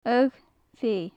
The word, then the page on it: agh feidh: gaelic